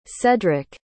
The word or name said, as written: cedrick